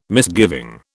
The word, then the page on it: misgiving: British English